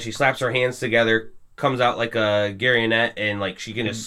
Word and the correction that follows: garrote: How is it pronounced? – Please. – It's garrote. – Garrote? – Garrote. – Garrote. – You said a garionette. – Yeah, isn't that what it is? – Garrote.